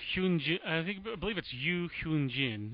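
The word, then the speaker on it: Ryu Hyun-jin: I believe it's Yu Hyun Jin, if you pronounce it Korean fashion.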